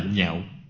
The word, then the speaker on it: nhạo: Cái chữ này đọc khác đi, không đọc là, đọc là nhạo.